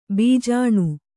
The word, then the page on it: ಭೂಜಾನಿ: Kannada